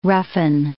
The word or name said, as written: roughen